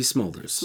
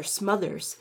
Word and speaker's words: Smulders: Kobe Smulders. – Smulders. – Like a fire. – I always want to call her Smothers. I don't know why. Colby Smulders. – I like Kobe Smulders better.